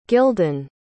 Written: Gyldan

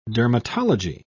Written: dermatology

